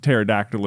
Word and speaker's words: pterodactyl: Whereas like pterodactyl is spelled with a silent P and then the T.